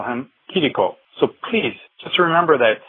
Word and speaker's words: Chirico: It's Kiriko. I know it's spelled C-H-I-R-I-C-O in English, but it's C-H as in Christ, not as in church. Look at the katakana spelling. It's Kiriko.